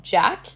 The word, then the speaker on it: JAC: And one of my favorite spots that I went to, super close to both of the hostels that I stayed at, was called Jack. It's spelled J-A-C.